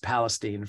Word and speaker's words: Palestine: By the way, it's pronounced Palestine from what I understand.